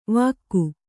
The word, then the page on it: ವಾಕು: vāku